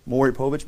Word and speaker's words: Maury Povich: You know, the Maury Povich, is that how you say it? Yep. Maury Povich.